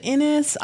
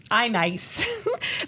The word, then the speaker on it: iNiS: I always pronounce it Innis, I-N-I-S. – Oh, yeah. – Who made the Undon games and like some other rhythm games. I-Nice.